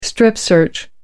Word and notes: strip-search: American English

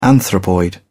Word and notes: anthropoid: British English

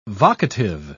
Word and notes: vocative: British English

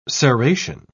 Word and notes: serration: British English

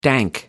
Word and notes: dank: American English

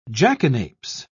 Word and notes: jackanapes: British English

